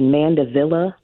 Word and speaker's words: Mandevilla: Mandevilla.